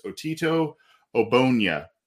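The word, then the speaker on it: Otito Ogbonnia: The way I've understood his name to be pronounced is Otito Obonya And he is interior defensive lineman from UCLA.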